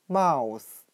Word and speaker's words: mouse: 它的发音很像汉语的“ 猫无死”。我们可以这样来想象：老鼠见到猫，肯定会感慨：“ 我今天一定会死啊！” 那今天我们所学习的单词。 mouse，老鼠，m o u s e，我们就可以通过它的发音联想到汉语的“ 猫无死”，见到猫吾必死。